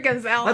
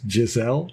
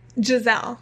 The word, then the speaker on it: Giselle: Call her Gazelle. – That's what I always think of when she looks like a gazelle. Anyhow, when she's pronounced Giselle. Gazelle? What is it? – Giselle.